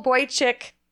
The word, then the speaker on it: Boychik: So it's Boychik Bagels, and it's spelled B-O-I-C-H-I-K. And it's a Yiddish word. It's normally spelled B-O-Y-C-H-I-K. And it's pronounced Boychik, and in Yiddish, it means cute little boy.